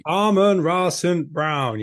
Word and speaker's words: Amon-Ra St. Brown: I can't even get his name right. – Amon-Ra St. Brown. You've got to say it in the Scott Hansen voice. – Yeah, I always get it wrong. I always want to call him Amon-Ra. No, Amon-Ra. Oh, I don't know. I don't know what I'm talking about. – You've absolutely butchered that one, mate.